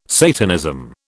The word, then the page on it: Satanism: British English